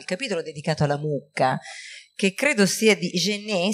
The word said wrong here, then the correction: Jenner: si legge Genet, Jenner, oh, quindi non è francese,